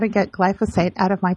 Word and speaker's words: glyphosate: And I finally just got disgusted with the fact that you can, how do you pronounce that word, glyphosate? – Yeah, glyphosate. – Mm-hmm. Is that how you pronounce it? – Yeah.